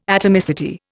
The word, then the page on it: atomicity: Indian